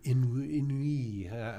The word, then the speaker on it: ennui: And about the scenery and the ennui.